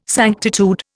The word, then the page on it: sanctitude: Indian